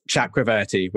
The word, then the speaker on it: Chakraverty: I'm gonna have to spell it, but it's M S Chakraverty, which is C H A K R A V E R T Y, and that's on all social media platforms.